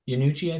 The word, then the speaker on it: Iannucci: Iannucci, I think is how it's pronounced.